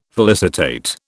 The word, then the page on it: felicitate: British English